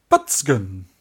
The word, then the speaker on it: Batsugun: Do you want to know how to pronounce it properly? – Yeah, go on. – Batsugun. – Batsugun. – Batsugun. – Can I say Batsugun, though? – Yeah, you can. We always do.